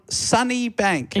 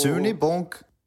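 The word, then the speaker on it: Sunnybank: Sunny Bank. – Incorrect. Incorrect. – Sunny Bank in Queensland. Logan Husky. – Ooh. – It's Sunny Bonk.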